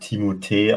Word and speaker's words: Timothée: es wird Timothée ausgesprochen.